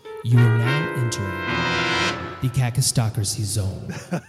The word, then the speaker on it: kakistocracy: K-A-K-I-S-T-O-C-R-A-C-Y, cacistocracy.